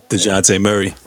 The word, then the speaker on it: Dejounte: and y'all relying on a young, how do you say, DeJounte or DeJounte? Yeah, DeJounte Murray.